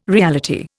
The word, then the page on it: reality: Indian